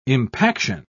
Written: impaction

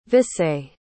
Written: visé

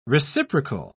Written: reciprocal

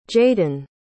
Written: jaden

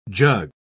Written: jug

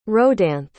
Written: rhodanthe

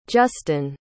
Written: justen

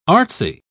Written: artsy

